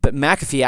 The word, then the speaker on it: McPhee: Anyway, and that company and that founder of that company is kind of an interesting story, but McAfee actually would be a closer phonetic to phonetically is closer to the original Gaelic, but McPhee is how we have the name handed down to us.